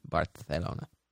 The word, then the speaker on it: Barcelona: It's the proper pronunciation, Barcelona.